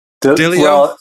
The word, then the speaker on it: Delio: Is it Ilia Delore or... – Delio.